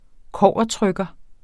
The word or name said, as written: kobbertrykker